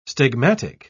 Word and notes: stigmatic: British English

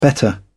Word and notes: better: British English

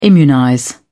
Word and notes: immunize: British English

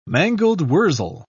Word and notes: mangold-wurzel: British English

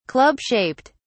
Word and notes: club shaped: British English

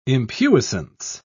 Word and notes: impuissance: British English

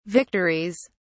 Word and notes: victories: British English

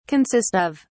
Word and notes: consist of: British English

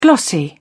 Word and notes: glossy: British English

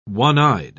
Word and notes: one-eyed: British English

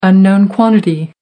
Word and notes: unknown quantity: American English

